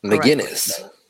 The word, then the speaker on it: McInnes: But yeah, I would like to understand his, you know, his relationship with Gavin McInnes, like McCain. I don't know how to pronounce that. – McInnes. – McInnes, sorry, Gavin McInnes.